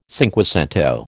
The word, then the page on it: cinquecento: American English